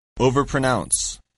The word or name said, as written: overpronounce